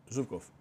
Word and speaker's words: Ζούπκοφ: Πρώτον, πώς λέγεται, είναι Ζούπκοφ ή Ζουμπκοφ? – Ζούπκοφ.